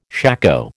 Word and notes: shako: American English

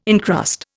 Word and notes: incrust: Indian

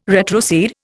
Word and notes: retrocede: Indian

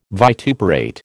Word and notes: vituperate: American English

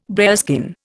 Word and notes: bearskin: Indian

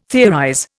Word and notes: theorize: Indian

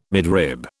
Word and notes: midrib: British English

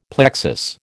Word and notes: plexus: American English

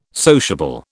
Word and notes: sociable: British English

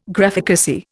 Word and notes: Graphicacy: Indian

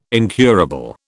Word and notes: incurable: British English

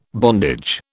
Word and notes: bondage: British English

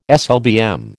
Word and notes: SLBM: American English

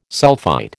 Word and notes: sulphite: American English

